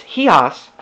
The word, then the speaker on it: Chios: The next day, we set sail from there and arrived off Chios. And I think I said that wrong. It's Chios. Chios, sorry. See, it looks like Chios, though. Anyways, it's Chios is what it is.